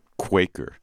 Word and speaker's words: Kveikur: I'm told it's pronounced Quaker, but if I spell it for you, you won't think that, because it's K-V-E-I-K-U-R, or Kveikur, if you want to go, like, more Yiddish than Icelandic.